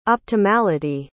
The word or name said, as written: optimality